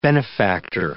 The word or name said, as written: benefactor